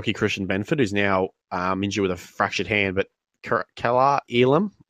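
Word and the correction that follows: Kaiir Elam: probably not pronounced that correctly, Kaiya Elam, there we go.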